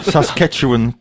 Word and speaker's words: Saskatchewan: I'm a network administrator / computer technician for a rural school district in Saskatchewan, Hatcha Ketchikan, Canada. – That's not how you pronounce it, is it? – Saskatchewan, Canada.